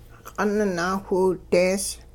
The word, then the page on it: q'ung naawhiwidees: Hupa